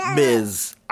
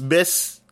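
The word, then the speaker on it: Ms.: I mean, I remember being taught this in school, but I remember looking it up because didn't know you pronounced it Miz. I thought it was just Miss, and you just spelled it different that you could never tell. But right. somebody said, Miss, to say Miz.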